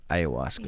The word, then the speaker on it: ayahuasca: Ayahuasca? – Ayahuasca, yeah.